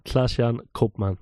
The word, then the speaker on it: Klaas-Jan Koopman: It's Klaas-Jan Koopman. – Klaas-Jan Koopman.